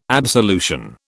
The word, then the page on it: absolution: British English